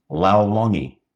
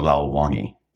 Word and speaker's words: Lao Longi: It's Lao, like in loud, but no D. So Lao. And then Long, Lao. Not short, Long. And then E, Lao Longi.